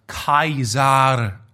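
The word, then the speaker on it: Caesar: Unser Lateinlehrer meinte mal, dass Cäsar eigentlich Kaisar ausgesprochen wurde.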